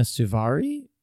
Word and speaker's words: Suvari: And like a big part of it is that I can never say her name. Mina Servino? – Mina Suvari? – That's it. Thank you. – Isn't it? – Yes. Mina okay. Suvari. Her character was like fat in uh-huh.